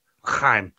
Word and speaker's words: L'chaim: Just say it right, man. L'chaim. – Yeah. – It's like you're going to cough up something.